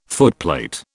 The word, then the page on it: footplate: British English